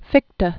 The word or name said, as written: Fichte